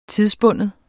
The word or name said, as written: tidsbunden